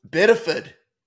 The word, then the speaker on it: Biddeford: Biddeford. You got to drop the ah. Biddeford.